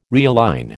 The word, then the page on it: realign: American English